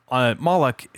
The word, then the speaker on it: Moloch: So Moloch is two syllables.